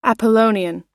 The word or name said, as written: apollonian